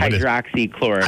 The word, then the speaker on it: hydroxychloroquine: how do you pronounce it? Chloroquine. – Hydroxychloroquine. – Hydroxychloroquine. They're buying it up just in case they need to distribute it.